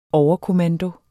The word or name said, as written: overkommando